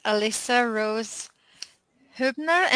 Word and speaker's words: Alyssa Rose Huebner: Everyone, I am here today with Alyssa Rose Huebner.